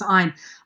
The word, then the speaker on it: Ayn: She pronounced it, it looks like it should be Ayn, but it was Ayn.